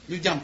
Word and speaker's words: людям: Людям, правильно же ударение слова? Людям. Людям.